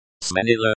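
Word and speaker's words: сменила: Smenila. Pronunciation. Sminila. Stress on the second syllable.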